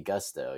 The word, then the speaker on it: Gusto: make sure I pronounce Gusto. It should be Gusto. Yes. See, that's the thing, is you look at that one time, everybody says it incorrectly, but very interesting brand out of Atlanta.